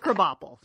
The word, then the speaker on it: Krabappel: And for those of you that don't watch The Simpsons, Krabappel is actually crab apple. Just pronounced Krabappel.